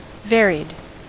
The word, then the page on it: varied: American English